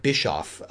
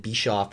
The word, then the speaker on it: Bischoff: I mispronounced the name of Eric Bischoff. I said Bischoff or something like that. It's supposed to be Bischoff like Bishop.